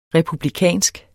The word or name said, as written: republikansk